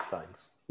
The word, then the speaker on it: Fiennes: Ray Fiennes' brother, Joseph Fiennes. – That's the one. Thank you. Fiennes is how you say it.